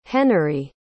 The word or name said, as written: Henery